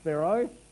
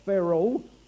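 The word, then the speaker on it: phero (φέρω): The word moved there is the Greek word phero, P-H-E-R-O, pronounced phero, not feral, phero.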